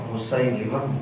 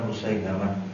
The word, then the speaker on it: Musailimah: Yang tepat bacanya dengan kasrah Musailimah, bukan Musailamah. Musailimah Al-Kadzdzab. Demikian juga ada Al-Aswad Al-Insi dari Yaman.